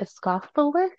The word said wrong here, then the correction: esophagus: I can't say that word. It's like the inside, like esophagus. Is that right? Esophagus. Anyways, they're saying like yeah, that entire. – Am I saying that right? Yeah, esophagus. That's how you say it.